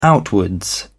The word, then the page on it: outwards: British English